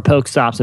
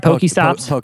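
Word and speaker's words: PokéStop: Am I saying it right? Poke stops. – Yeah. – I don't really care. Yeah. Whatever. – Yeah. – Whatever, nerd. – Nerd. Next. – Pokestop works. Yeah.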